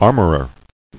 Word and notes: Armorer: ar·mor·er

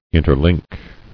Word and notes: Interlink: in·ter·link

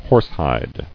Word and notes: Horsehide: horse·hide

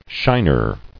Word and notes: Shiner: shin·er

